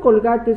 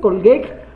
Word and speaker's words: Colgate: Si vienes a decirme, no se dice colgate, se dice colguec, no espero que de tu persona salga un aiga.